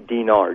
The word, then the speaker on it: dinar: Did you say the dinar? I hope I'm saying that right. Okay. – Iraqi dinar, D-I-N-A-R.